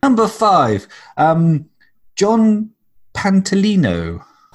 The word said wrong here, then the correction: Pantoliano: You might have just noticed that I said that guy's name wrong. His actual name is Joe Pantoliano.